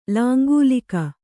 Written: ಲಾಂಗಲಿಕ

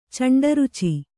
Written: ಚಂಡರುಚಿ

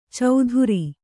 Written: ಚೌಧುರಿ

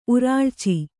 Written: ಉರುಳ್ಚು

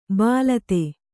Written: ಬಾಲತೆ

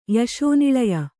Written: ಯಶೋನಿಳಯ

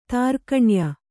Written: ತ್ರೈಗುಣ್ಯ

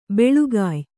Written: ಬೆಳಿಗ್ಯೆ

